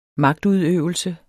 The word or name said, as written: magtudøvelse